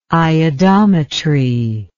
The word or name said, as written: iodometry